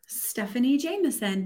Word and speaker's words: Stephanie Jameson: Stephanie Jameson, but when you say it, love, that's Ooh.